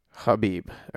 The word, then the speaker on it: Khabib: Khabib, I believe is how you say his name properly.